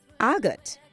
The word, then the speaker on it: argot: It is spelled A-R-G-O-T. You may be wondering why not say argot. But the pronunciation that I'm giving to you is the British pronunciation that we use in standard English in Guyana, as opposed to the American pronunciation. So it is argot, and it is spelled A-R-G-O-T.